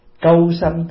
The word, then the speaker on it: câu sanh: Cái chữ cu á, đúng là mình đọc là câu. Câu sanh tức là khi mình sanh ra có một ông thần cùng sanh với mình, gọi là câu sanh mà.